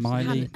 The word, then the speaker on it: Miley: Miley.